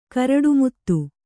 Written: ಕರಡುಮುತ್ತು

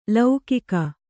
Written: ಲೌಕಿಕ

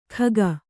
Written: ಖಗ

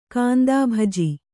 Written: ಕಾಂದಾಭಜಿ

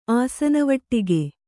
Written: ಆಸನವಟ್ಟಿಗೆ

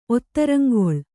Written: ಒತ್ತರಂಗೊಳ್

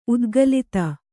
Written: ಉದ್ಗಲಿತ

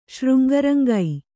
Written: ಶೃಂಗರಂಗೈ

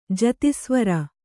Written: ಜತಿಸ್ವರ

